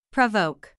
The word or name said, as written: provoke